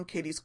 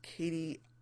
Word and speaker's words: Kati: This is the one that we have now learned is pronounced Kati, not Katie. So I will try to remember that from now on.